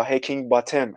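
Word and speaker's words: Heckingbottom: هکینگ باتوم یا هکینگ باتم اگه اسم بخوایم حالا خیلی تلفظ دقیق تری رو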